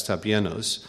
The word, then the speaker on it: tapeinos: T-A-P-E-I-N-O-S, tapianos.